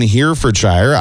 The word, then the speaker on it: Herefordshire: The popular Scoop ice cream shack in Herefordshire, I actually said that properly.